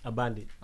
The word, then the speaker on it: Abandé: Euh, on va commencer par, euh, tu me dis si je prononce bien, Abandou. – Abandé. Abandé. Abandé.